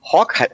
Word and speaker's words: Haack: Ähm, ich muss auch sagen, nach der ersten Saison von Matt Hawk wird er übrigens, soweit ich weiß, ausgesprochen.